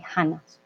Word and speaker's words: Hannah: So if we say the, if we see the H, although we don't pronounce it, we say Hannah.